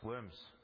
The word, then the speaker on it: Worms: And it was in the German city of Worms, which in English is pronounced Worms.